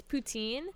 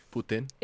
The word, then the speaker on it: Putin: Okay, so the reason is so in English it's Putin, but in French, which my family is French, Putin. – It's Putin. Putin.